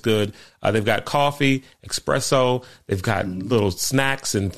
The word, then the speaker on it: espresso: This is just a pronunciation thing. Espresso.